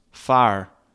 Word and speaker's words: fear: That didn't pan out, but I do know that it's not fear, it's pronounced fire.